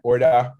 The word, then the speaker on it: Orda: It's got a little bit of a twang like Orda, but Orda, we'll a little accent at the end.